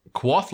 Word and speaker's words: Kvothe: Quoth, like it's spelled K-V-O-T-H-E. – Oh, okay. – But it's actually pronounced, Quoth or Quoth.